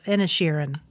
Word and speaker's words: Inisherin: The, the Banshees of Innershin. Apparently, I am having trouble pronouncing this movie all the way around. It should be the Banshees of Inisherin, starring Colin Farrell and Brendan Gleeson.